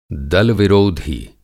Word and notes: दल-विरोधी: Hindi

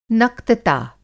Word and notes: नक्तता: Hindi

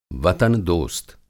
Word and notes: वतन-दोस्त: وطن دوست

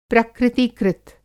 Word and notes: प्रकृति-कृत: प्रकृतिकृत; Hindi

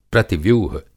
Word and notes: प्रतिव्यूह: Hindi

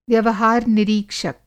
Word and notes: व्यवहार-निरीक्षक: Hindi

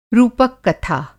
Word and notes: रूपक-कथा: Hindi

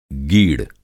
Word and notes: गीड़: masculine noun

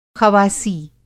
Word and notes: ख़वासी: خواصی

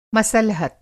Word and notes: मसलहत: Hindi

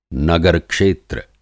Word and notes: नगर-क्षेत्र: Hindi

